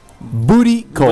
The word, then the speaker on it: booty call: Lo pronuncié mal. No me entendiste vos tampoco. – No. – Un bergoglio. – Quise decir burricol. – Booty call.